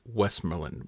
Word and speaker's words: Westmoreland: I would normally, and I'm not from that part of the country, but I would normally pronounce it Westmoreland, with the two vowels in the back half of the word almost rhyming. Both of them just kind of schwa sounds. In the U.S., I would expect people to pronounce it with more of an O sound to the O, right? So the O, like more.